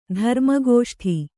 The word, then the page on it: ಧರ್ಮಗೋಷ್ಠಿ: Kannada